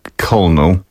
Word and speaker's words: colonel: S-I-X-T-H. Another word. Mm. Pułkownik. – Colonel. – Tak. – I don't know how to say it. – Pisze się colonel, a wymawi się colonel.